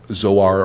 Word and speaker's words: Zoar: Now that's the way I pronounce it because there's a Zoar, Ohio, close to where I live, so Zoar or Zoar.